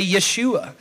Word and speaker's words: Yeshua: But Joshua, if you were to pronounce it in Hebrew, it would just simply say Yeshua, which is the same exact name as Jesus.